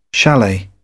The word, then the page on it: chalet: British English